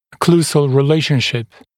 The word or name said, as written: occlusal relationship